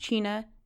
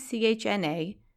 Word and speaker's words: CHNA: with an abbreviation sometimes pronounced CHENA, sometimes CHNA, tomato, tomato.